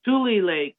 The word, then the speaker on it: Tule Lake: Tule Lake, yes.